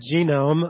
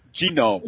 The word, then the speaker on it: genome: But we have a new exhibition opening this Saturday called Genome: Unlocking Life's Code, and it's about. – I didn't even pronounce it correct. Genome. – Genome. – There's no extra O in there.